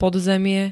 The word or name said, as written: podzemie